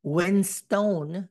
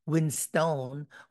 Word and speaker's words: Winstone: Produced by Joe Roth, Jeff Christianbaum, and Chris Castaldi, starring Eleven herself, Millie Bobby Brown, Ray Winstone, not Winston, Winstone.